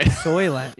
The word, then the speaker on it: Soylent: I mean, yeah, you probably got good organs eating all that Salians, you know? – Yeah, man, couldn't even pronounce it right. – Soylent. Yeah, what was Salient? – Oh, man.